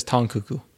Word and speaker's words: Tang Keke: Apparently, it's spelled Tang Keke, but she says Tong Cuckoo.